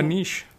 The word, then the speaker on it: niche: I want to have a niche. – Yeah. So is it because it rhymes with quiche? – Yeah.